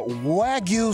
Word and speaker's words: Wagyu: uh, Wagyu steak did I yeah.